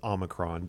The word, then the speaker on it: Omicron: Is it Omicron or Omicron? Because it's obviously the variant of the moment. – I think the correct pronunciation is Omicron, but we're hearing people pronounce it in many different ways.